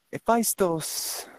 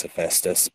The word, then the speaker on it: Hephaestus: By the way, Hephaestus is Hephaestus, but Hephaestus is the more Greek pronunciation, so that's why I say it.